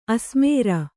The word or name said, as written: ಆಸೆಮಾರಿ